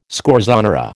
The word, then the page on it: scorzonera: American English